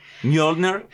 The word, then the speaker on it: Mjolnir: Mjolnir.